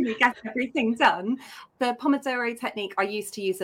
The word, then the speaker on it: Pomodoro: Have you ever tried the Pomodoro technique? I don't know if I'm pronouncing that correctly. – No, you have. I think you've pronounced it perfectly. A Pomodoro technique.